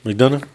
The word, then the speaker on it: McDonough: And then the star off the kickoff show is going to be Sami Zayn versus, is that JD McDowell? – Yeah, JD McDonough. – McDonough? How do you say that? – It's McDonough.